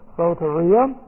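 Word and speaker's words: soteria (σωτηρία): And then there is soteria. And we'll take a look at this word. This is S long O, T long E, R I A.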